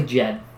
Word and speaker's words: Djed: D-J-E-D. – It's probably Djed. – I think it's Djed. With like a, almost like a little bit of an emphasis on the J for the D, the Djed. Like a J.